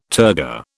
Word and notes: turgor: British English

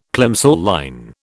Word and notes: Plimsoll line: British English

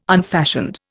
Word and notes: unfashioned: Indian

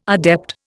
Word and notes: adept: Indian

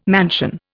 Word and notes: mansion: Indian

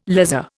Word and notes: lazar: Indian

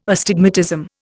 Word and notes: astigmatism: Indian

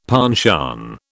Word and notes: penchant: British English

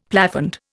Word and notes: plafond: Indian

